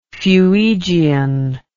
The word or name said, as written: fuegian